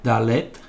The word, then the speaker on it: Dalet: Ha sacado Elvira número 4, que eh, se pronuncia Dalet, d a l e t a -A -H,